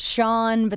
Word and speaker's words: Shenae: Yeah, and apparently mine, it's like an Irish Russian spelling, and it's pronounced Sean, but then it's like, I have these extra A's in it,